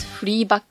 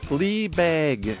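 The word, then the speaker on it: Fleabag: やるべきリストってことですね。で、反対に次は女の子がどんなテレビドラマが好きかっていう話をしてくれるんですけど、私はフィービー・ウォーラー・ブリッジが企画してるフリーバッグっていう話が超好きっていうのを言ってます。So, how do you pronounce this free bag in English? – Right. Flea bag.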